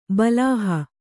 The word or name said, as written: ಬಲಾಹ